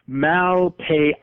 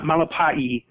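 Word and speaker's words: Malepeai: It's Vavai Malpai, not Malapai. I was trying to be fancy with it, I guess.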